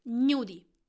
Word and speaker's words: gnudi: Today's word is gnudi. How to spell it? G-N-U-D-I. Gnudi. In Italian language, G-N is pronounced gnu. It is not gnu. So for the same reason, you would say gnocchi and not gnocchi.